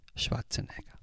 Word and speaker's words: Schwarzenegger: Por cierto, eh, Antonio Rentero, en tu último podcast dijiste Schwarzenegger. Eh, que sepas cómo se pronuncia, ¿eh? Schwarzenegger. Y ahora sí, vamos con las...